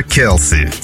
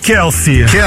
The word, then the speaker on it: Keelse: KLC. Пишется как K-E-E-L-S-E. – То есть, знаешь, как сказать, а описать попытался, да? Келси. – Келси.